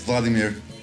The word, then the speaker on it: Vladimir: Yes, Vladimir.